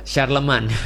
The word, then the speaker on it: Charlemagne: Oh, Charlemagne bacanya apa? – Charlemagne. – Oh, Charlemagne. – Charlemagne.